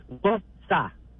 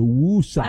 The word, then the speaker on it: Worcester: Yeah, two syllables. Worcester. – The Woosa.